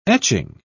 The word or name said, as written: etching